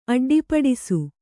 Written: ಅಡ್ಡಿಪಡಿಸು

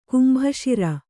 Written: ಕಂಬಿಸೀರೆ

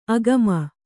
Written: ಆಗಾಮಿ

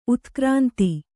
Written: ಉತ್ಕ್ರಾಂತಿ

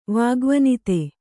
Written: ವಾಗ್ವನಿತೆ

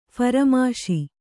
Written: ಫರಮಾಶಿ

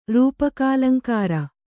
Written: ರೂಪಕಾಲಂಕಾರ

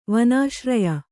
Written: ವನಾಶ್ರಯ

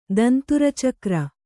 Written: ದಂತುರಚಕ್ರ

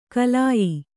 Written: ಖಲ್ಯೆ

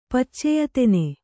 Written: ಪಚ್ಚೆಯತೆನೆ

